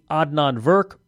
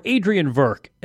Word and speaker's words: Adnan Virk: Be sure to check out previous episodes as well, including Adnan Virk or Adrian Virk, as people mispronounce it.